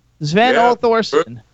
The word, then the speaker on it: Sven-Ole Thorsen: Sven Ole-Thorsen.